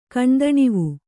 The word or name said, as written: ಕಣ್ದಣಿವು